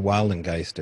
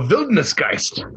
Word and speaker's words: Wildnisgeist: Describe to my audience what a Wildengeist is. Am I saying it right? – No, Wildnisgeist. Die Wildnisgeist.